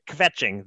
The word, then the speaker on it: kvetching: We need to work on your pronunciation of Yiddish words. You put like a little extra vowel sound in there. It's kvetching. The K and V go right together. You said sort of it was a bit of a kvetching. So let's work on kvetching.